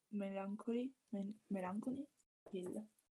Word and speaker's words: Melancholy Hill: Melancholy Hill.